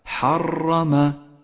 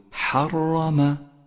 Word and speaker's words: حرم: احذر عند نطقها من تكريرها أي هكذا حرم فهذا خطأ شائع صوابه أن تقول حرم